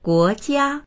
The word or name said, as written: guo2jia1